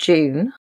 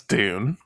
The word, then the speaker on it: Dune: So after we'd had our Christmas feast, we watched, I'm going to pronounce it the English way. We watched June, but I think you guys would say Dune. – Yes, Dune.